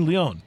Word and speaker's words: Lyon: So the famed French soccer team is called Lyon, or Lyon, I think is the correct pronunciation. Lyon. But it's spelled like Lyon, L-Y-O-N.